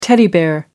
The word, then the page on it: teddy bear: British English